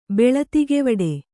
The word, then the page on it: ಬೆಳತಿಗೆವಡೆ: Kannada